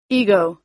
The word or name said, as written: ego